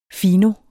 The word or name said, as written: fino